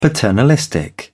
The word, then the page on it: paternalistic: British English